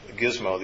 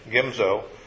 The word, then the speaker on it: Gimzo: This is one we pronounced Gizmo the other day. Gimzo, also in the towns thereof, and they dwelt there.